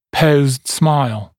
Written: posed smile

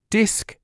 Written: disc

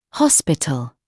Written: hospital

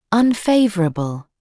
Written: unfavourable

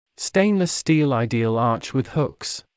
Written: stainless steel ideal arch with hooks

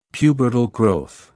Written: pubertal growth

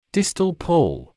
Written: distal pull

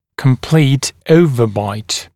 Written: complete overbite

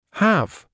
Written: have

